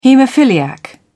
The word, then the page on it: haemophiliac: British English